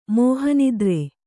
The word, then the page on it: ಮೋಹನಿದ್ರೆ: Kannada